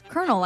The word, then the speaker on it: colonel: I think I'm going to go with kernel, because it's actually spelled like colonel, but it's kernel, like a popcorn kernel.